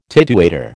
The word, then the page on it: tidewaiter: American English